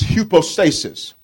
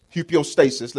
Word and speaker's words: hypostasis: Now, the second level of faith is hypostasis. Hypostasis. Let me spell it for you note takers. H-U-P-O, stasis. S-T-A-S-I-S. Hypostasis.